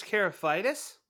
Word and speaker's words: Karlaftis: George Karlaftis from Purdue. – Really? Yes. George Karlaftis? – There it is.